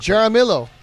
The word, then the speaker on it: Jaramillo: You know, the hitting coach was Rudy Yarillo. God, I can't Jaramillo. – Jaramillo, thank you.